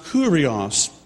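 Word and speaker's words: Kurios (Κύριος): In Greek, it's pronounced Kurios.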